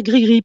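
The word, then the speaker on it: Gris Gris: Alors, comment on prononce les Grey Greys, les Gris alors, on dit Grigri, mais le i a été transformé en y pour que leurs fans anglais, anglophones, puissent correctement euh, le, prononcer pour qu'ils continuent à dire gris-gris parce que avec le i ça faisait graille-graille en anglais.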